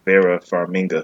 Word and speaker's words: Vera Farmiga: and Vera Farmiga. I think that's how you say her name. – I believe so, yeah.